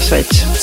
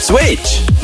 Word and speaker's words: Schweiz: Nej, jag skrattar för att du inte kan uttala Schweiz. – Schweiz? Säger jag fel? – Schweiz. – De schweiziska fansen. – Inte Schweiz.